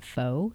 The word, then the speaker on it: pho: It's spelled P-H-O, so a lot of people call it pho. So if that's what you've heard, it's actually pronounced pho.